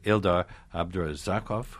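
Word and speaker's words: Ildar Abdrazakov: My great thanks to Ildar Abdurazakov, who is Prince Igor, in my mind anyway, and Anita Rochevelishvili. – Great. – Did that okay? – Great pronunciation, yes.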